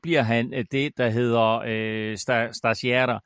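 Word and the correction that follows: stagiaire: eh, øh, hvordan udtaler man det, Kim, sådan helt, sta, sta, sta, på italiensk, stagiare. Stagiare. – Stagiare. Stagiare. – Stas, bare uden i? E, stagiare. Okay, stagiare.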